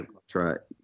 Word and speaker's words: Socotra: Socotra, Yemen.